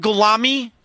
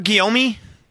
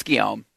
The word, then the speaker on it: Guillaume: It's Guillaume. Yeah, that's right.